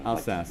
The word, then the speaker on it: Alsace: So Hindemith was conscripted into the German army in September of 1917 and sent to join his regiment in Alsace in January of 1918. Did I say that right? – Alsace. Like they speak they speak Alsatian there.